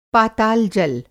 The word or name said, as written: पाताल-जल